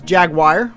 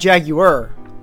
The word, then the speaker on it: Jaguar: He said Jaguar. I said Jaguar. The U was pronounced. I might just specify the U is pronounced.